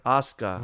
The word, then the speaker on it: Asuka: I was starting to like Ahsoka. – Asuka. We're not talking Star Wars.